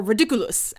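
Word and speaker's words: Riddikulus: You picture the way to make it funny very clearly in your mind, then when the boggart appears, you use the incantation Ridiculous. Or Ridiculous, as David Thewlis pronounces it.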